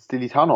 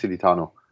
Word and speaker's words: Stilitano: Ähm, ja, auf jeden Fall, mein Nachname wird Stilitano ausgesprochen, also eigentlich genauso, wie man es schreibt. Aber mhm. tatsächlich wird das sehr oft falsch ausgesprochen. Aber Carmine ist Celitano.